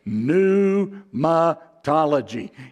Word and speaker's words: pneumatology: I wrote on the whiteboard Tuesday night, pneumatology. And then I even gave the pronunciation of it. N-O-O-M-A-T-O with a long thing.